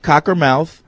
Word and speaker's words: Cockermouth: And yes, Fred, it is pronounced Cockermouth.